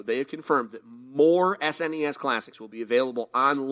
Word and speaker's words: SNES: So the other two pieces of information that they have provided is, number one, they have confirmed that the SNES Classic, which, by the way, that's the correct way to say it, not SNES, S-N-E-S, you monster.